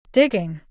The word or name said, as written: Digging